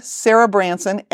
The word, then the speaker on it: Sarah Branson: My name, Sarah Branson, S-A-R-A-H.